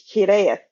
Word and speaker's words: Hiraeth: This is a Welsh word. It is pronounced Hiraeth.